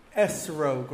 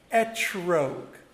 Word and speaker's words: etrog: Our oral tradition tells us that that is referring to a fruit that I have right over here called a esrog, or in Sephardic pronunciation, a etrog.